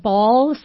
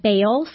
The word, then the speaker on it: Baal: Kathy pronounces it Baal. Some of you may have heard it pronounced Baal, same yeah. B-A-A-L, same thing.